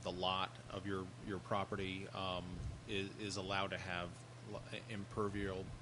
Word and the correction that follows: impervious: Am I saying that right? – Yeah, pervious versus impervious.